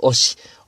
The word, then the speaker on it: 御師: という風な字を書いて、えー、一番最初の 5 とですね、最後の C、えー、これがくっついて、えー、漢字の読み方が変わって、おし、音師になったという説が、まあ、有力だそうですね。